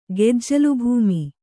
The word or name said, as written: ಗೆಜ್ಜಲುಭೂಮಿ